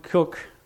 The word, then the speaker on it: Coke: Roger Williams was the informally adopted son of Edward Cook, or Coke it's spelled,